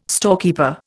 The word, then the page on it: storekeeper: Indian